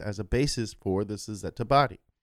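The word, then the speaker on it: Cizeta: In 1991, a handful of ex-Lamborghini Machine employees, led by Claudio Zampoli, jumped into the pool and launched the Suzetta Moroder V16T. That's how it's pronounced.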